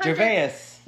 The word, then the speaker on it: Gervais: Gervais.